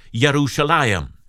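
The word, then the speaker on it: Yerushalayim (ירושלים): And if you don't know it, the name Jerusalem, that word in Hebrew, is pronounced Yerushalayim.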